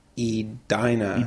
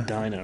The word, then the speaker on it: Edina: He listened to a little of episode 14, and we pronounced Edina, Minnesota, or we pronounced it Edina, Minnesota, and it is Edina. – Edina, right.